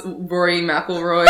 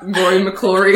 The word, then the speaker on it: McIlroy: Do you teach them when we pronounce Rory McIlroy, like Rory McClory? – Like, it's cause the capital I and the L look the same.